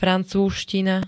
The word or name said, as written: francúzština